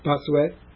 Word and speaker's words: Bossuet: How would you pronounce that? – Bossuet.